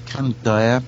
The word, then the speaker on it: Kandiah: So if you just Google my name, it's Krish Kandaya. It's spelled K-A-N-D-I-A-H.